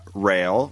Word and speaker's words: Rael: You've got Rael, which mm-hmm. is spelled R-A-E-L, like the end of Israel.